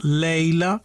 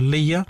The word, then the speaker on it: Leia: Iniziamo dal 1977, Una nuova speranza, poi La minaccia fantasma del 1999 e ancora Una nuova speranza con una curiosità: in italiano la principessa Leila, in realtà in inglese aveva un'altra pronuncia, Leia, ma non era adatta per il pubblico italiano.